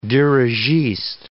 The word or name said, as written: dirigiste